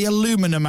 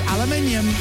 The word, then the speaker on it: aluminium: Well, tomorrow, because we're going all American at 9 o'clock, we're doing the Aluminum Hour. That's right. Americans can't pronounce aluminium. They call it aluminum.